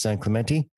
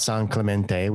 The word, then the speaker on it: San Clemente: For those of you that don't live in California, it's San Clemente. It's not San Clemente. We don't actually pronounce it with the Spanish if.